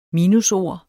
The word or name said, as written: minusord